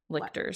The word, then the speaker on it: Lyctor: It's Lyctor. – Lictors, I think. – Because it'd be like lich, I guess.